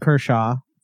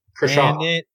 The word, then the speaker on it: Kershaw: So let's give him Clayton Kershaw for one of them. – Oh, you said it right, too. – Kershaw.